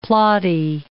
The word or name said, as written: plotty